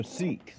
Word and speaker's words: Sikh: Is that sheets? Oh, Sikhs. – Sikhs. – Oh, Sikhs. – Or Sikh. – How do you spell that? – S-I-K-H. – Oh, the Sikh. – Sikh or Sikh. – Mm-hmm. Sikh, okay.